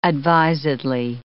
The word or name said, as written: advisedly